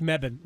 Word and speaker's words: Mebane: Mebane.